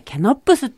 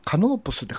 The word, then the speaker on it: カノープス: お店の名前ね、ケノップスって読むみたいよ。 – え、カノープスって書いてあるぞ、これ。